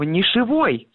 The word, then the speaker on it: нишевой: Дело в том, что словарях дается другой вариант этого слова – нишевой. Как бы произношение и образование, ну, то есть на основе этого прилагательного, вот такого прилагательного, какое вы говорите вы, с ударением на первом слоге и с окончанием «ый», это, ну, как бы ошибка.